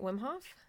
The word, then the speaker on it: Wim Hof: Am I saying that right? Wim Hof? – Yeah.